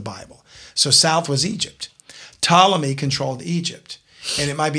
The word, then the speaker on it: Ptolemy: And incidentally, of the four generals, one of them was named Seleucid, and one of them was named Ptolemy, and it's PT. The P is silent.